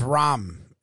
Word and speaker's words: Rahm: I've always heard it as Rahm. Does anyone call him Ram? Do people say John Rahm? – I'm going Rahm with the H.